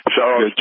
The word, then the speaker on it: Soros: Soros.